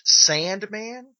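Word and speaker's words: S&Man: This one is called, it's pronounced Sandman, but right. It's S, the and sign, man.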